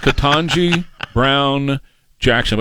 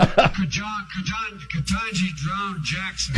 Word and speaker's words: Ketanji Brown Jackson: Her name is Ketanji Brown Jackson. But here's Joe saying. – Ketanji Drown Jackson.